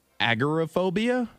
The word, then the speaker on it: agoraphobia: Now, she is a woman who suffers from, hope I pronounce this right, agoraphobia.